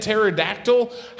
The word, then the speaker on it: pterodactyl: Like, if I can't understand in the English language why the P is silent in this and pterodactyl, how do we start to think about the Holy Spirit?